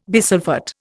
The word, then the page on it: bisulphate: Indian